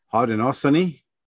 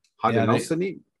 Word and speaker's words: Haudenosaunee: Haudenosaunee. Haudenosaunee.